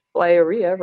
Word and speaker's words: Flyaria: Flyaria rhymes with diarrhea.